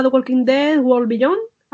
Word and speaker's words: The Walking Dead World Beyond: Se llamará The Walking Dead World Beyond. A lo mejor no se pronuncia así, yo lo pronuncio. – Sí, lo has dicho muy bien, lo has dicho muy bien.